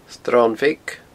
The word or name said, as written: sronbheag